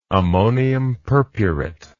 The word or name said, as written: ammonium purpurate